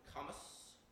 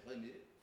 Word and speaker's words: Camus: Just to finish, during World War II, there was this dude from France named Albert Camus, or Albert Camus, for those of you who like bad French pronunciations.